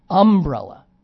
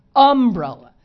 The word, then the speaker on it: umbrella: This isn't as egregious, but Pittsburghers seem to put the accent on the wrong syllable a lot to my ear. – Mm-hmm. – So it's umbrella. Now I don't know what the dictionary says. – Insurance. – You do know. Okay. Insurance. Umbrella. Most people don't say the words like that. They say umbrella, the second syllable.